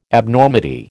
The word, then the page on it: Abnormity: American English